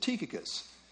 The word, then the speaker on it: Tychicus: I always pronounce this Tychicus, but when I listened online to how you should officially pronounce it, they said Tychicus. So, I guess it's Tychicus.